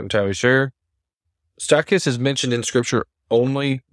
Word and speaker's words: Stachys: It's probably Stachys. S-T-A-C-H-Y-S. It's not Stachys or Stachys, but Stachys.